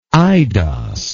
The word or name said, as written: aidos